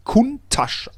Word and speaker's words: Countach: aber aus der Ecke kommt er und er wird ganz schlicht und ergreifend Countach ausgesprochen, wie die Menschen da vor Ort sprechen, wie die Mundart, das ist die korrekte Aussprache des Lamborghini.